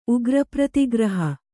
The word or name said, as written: ಉಗ್ರಪ್ರತಿಗ್ರಹ